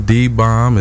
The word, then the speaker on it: Dee Bomb: Once again, I am Dee Bomb.